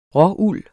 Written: råuld

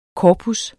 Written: korpus

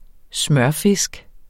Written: smørfisk

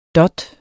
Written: dot

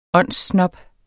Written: åndssnob

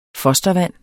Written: fostervand

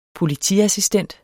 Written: POLITIASSISTENTENS

